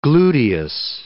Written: gluteus

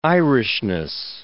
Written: Irishness

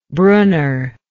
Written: brunner

